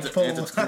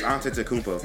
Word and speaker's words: Antetokounmpo: Antetokounmpo. – There you go. – I can't say his last name. – It's Antetokounmpo.